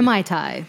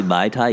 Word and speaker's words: Mai Tai: It's not actually Mai Tai. It's M-A-I-T-A-I is the correct way to say it.